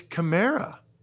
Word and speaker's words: Kamara: It's Kamara. It's his fourth year. He's been a great player for a long time. The TV announcers get it right. Kamara. It's in the pronunciation guide. Not Kamara. Kamara.